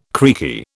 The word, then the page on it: creaky: British English